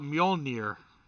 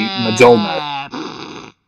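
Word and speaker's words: Mjölnir: What's so difficult about Mjolnir? Seriously. – Well, because a lot of people would probably, a lot of English-speaking people that don't know how it's pronounced would be Mjolnir.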